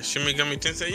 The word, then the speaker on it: Shin Megami Tensei: Are you excited for Shin Megami Tensei? Did I say that right? – Yeah, Shin Megami Tensei.